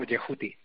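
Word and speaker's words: Djehuty: Bueno, correctamente nadie lo sabe, pero es más o menos algo así como Yehuti. – Yehuti. – ¿No? Porque los egipcios escribían las consonantes, pero no las vocales. Entonces la pronunciación exacta pues es un poco difícil. Supongo que según la parte de Egipto donde fueras, lo pronunciarías de una forma u otra, ¿no?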